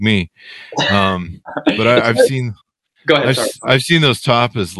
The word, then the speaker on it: tapas: You know, the thing about Spain is those tapas. Am I saying it right? The tapas? – Tapas. – Yeah. Tapas.